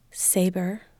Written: saber

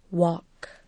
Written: walk

